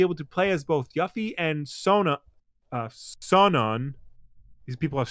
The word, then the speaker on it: Yuffie: It's Yuffie.